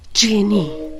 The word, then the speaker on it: جینی: جینی جیم کسره نونیه که میشه جینی. – تلفظش دقیقا مثل جینیه.